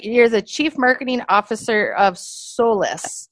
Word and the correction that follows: Solace: Did, did I say that right? Uh, solace. – Solace. Solace. I'm sorry.